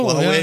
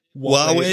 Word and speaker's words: Huawei: Ähm, wie spricht man's aus? Huawei. – Oh, Huawei. Ja. Huawei, genau.